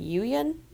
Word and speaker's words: Yuyan: While returning to his friends, he is attacked and captured by the, and correct me on the pronunciation, is it Yuyan archers? – Yeah.